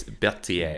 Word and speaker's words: Berthier: It's Berthier. – Berthier. – No, it's not a TH. It's a T.